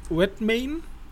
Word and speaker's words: Redmayne: In der Hauptrolle Eddie Redmayne.